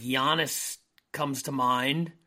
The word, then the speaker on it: Giannis: Giannis is correct.